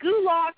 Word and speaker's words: Gulak: Is it Gulak, or is that how you pronounce his name? – Yes.